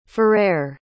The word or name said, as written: ferrer